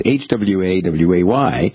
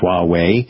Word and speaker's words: Huawei: I guess it's Huawei. I'm thinking it's pronounced Huawei.